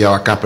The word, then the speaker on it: abacá: Ellos se encargan de lo que es el cacao, banano y abaco. Y abacá, perdón.